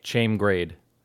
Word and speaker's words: Chaim Grade: Before I'm pretty sure it's pronounced Chaim Grade. – Chaim Grade, yeah.